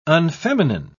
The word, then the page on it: unfeminine: British English